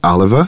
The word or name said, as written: Oliver